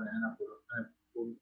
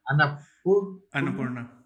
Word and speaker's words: Annapurna: in those kind of productions, like from Italy, for Annapurna. I never know how to say this name. – Annapurna.